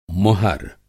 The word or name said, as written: मुहर